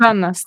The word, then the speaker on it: Janna: tényleg ő Jana vagy Jana? Hogy ejtik? – Jana. – Janának, jével.